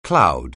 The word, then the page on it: cloud: anglais